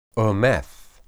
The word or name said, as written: emeth